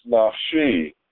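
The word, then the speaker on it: nafshi (נפשי): The translation of the verse, who has not lifted up his soul, nefesh, in vain, observe that it is written nafsho, his soul, but pronounced nafshi, my soul.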